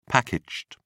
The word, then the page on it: packaged: British English